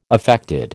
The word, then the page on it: affected: American English